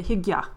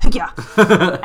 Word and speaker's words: hygge: they still say hygge. It's the old pronunciation. So they have quite a forceful pronunciation. Like, you don't just hygge, it's like hygge.